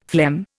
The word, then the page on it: phlegm: Indian